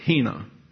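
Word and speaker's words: hina (ἵνα): God loved the world so much, He gave His uniquely born Son, that's Jesus Christ, so that we have the conjunction pronounced hina.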